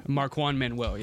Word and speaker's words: Marquand Manuel: Marquand Manuel, yeah. – Okay, Marquand. – You got it.